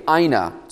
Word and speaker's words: hina: Now, between verses 37 and 38, you have what is called an Ina clause. If you're taking notes, you can spell it phonetically, H-I-N-A, Ina. It's a Greek word.